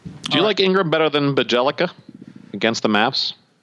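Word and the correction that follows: Bjelica: Your mispronounced name segment that we were just about to get into was not hardy enough, but that is quite possibly a Hall of Fame mispronunciation of Bajelica instead of Bielitsa.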